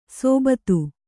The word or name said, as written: ಸೋಬತ್ತು